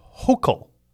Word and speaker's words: Hochul: I learned how to say Governor Hochul. It's interesting. I didn't know how to say her name. And if you remember, it's H-O-C-H-U-L. And the H appears silent.